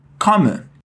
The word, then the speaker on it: komme: Come. Come. Percebeu que esse O aí é bem aberto? Ó.